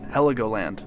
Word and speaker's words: Heligoland: I think I'm saying that right. Heligoland. There we go.